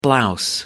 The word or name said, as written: blouse